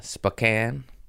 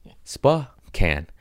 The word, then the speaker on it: Spokane: In Spokane is how you pronounce it, even though it's spelled Spokane. Spokane.